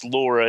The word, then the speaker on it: LoRa: This LoRa technology, it's pronounced LoRa, and it's short for long range.